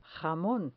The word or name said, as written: jamon